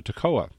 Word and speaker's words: Toccoa: There you go, Toccoa, Georgia. There we go. I looked up the diacritic. That's how you pronounce it.